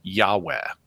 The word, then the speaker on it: Yahweh: And it's believed by scholars that those were most probably pronounced Yahweh early in Israelite history.